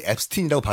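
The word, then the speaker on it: Epstein: 본인 스스로 자기 이름을 앱스틴이라고 발음해요.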